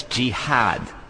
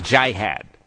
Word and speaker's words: jihad: But it's jihad, not jihad.